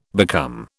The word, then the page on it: become: British English